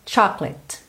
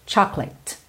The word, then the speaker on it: chocolate: Em inglês a gente vai ter chocolate, chocolate. Ou seja, não se fala chocolate, chocolate. Fica meio clate, clate, chocolate.